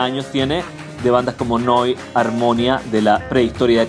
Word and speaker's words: Neu: Noi eh, que simplemente quiere decir nuevo en alemán, nuevo, se escribe Neu, se dice con un signo de exclamación, se dice Neu.